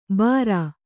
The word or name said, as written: ಬಾರಾ